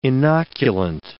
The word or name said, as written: inoculant